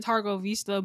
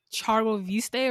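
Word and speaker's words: Târgoviște: And I heard that apparently in the TV show they pronounce it Targo Vista, but in Romanian it's like Chargo Viste or something like that.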